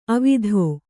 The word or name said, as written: ಅವತ್ತು